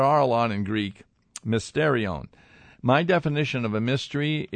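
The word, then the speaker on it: mysterion (μυστήριον): Now, the Greek word, by the way, I'll pronounce it for you, mysterion.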